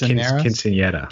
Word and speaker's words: quinceañera: Or it's good for Quincinetus also. – For what? – Quincinetus. – Oh, sweet six Spanish, Latino six, sweet 16. – Am I saying that right? – I think it's quinceañera.